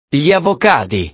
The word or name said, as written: gli avocadi